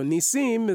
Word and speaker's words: Nissim: Before I'm Hasidic, I'm Sephardi. I'm Sephardi first. So Nissim is actually how you pronounce it.